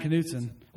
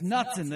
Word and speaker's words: Knutson: He's like, it's Knutson, the K's silent. And then he was like, no, it's Knutson.